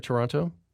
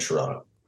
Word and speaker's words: Toronto: Yeah, I'm right in Toronto. – And I learned in the book that Americans say Toronto, but Canadians say Toronto, right? – Yeah, no, we just don't have patience for that last T.